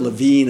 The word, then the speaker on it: Lewin: Kurt Lewin, Kurt Levine is the way he pronounced his own name. He was German, but Americans call him Kurt Lewin, a social psychologist, German refugee in the 30s and 40s, one of the founders of social psychology.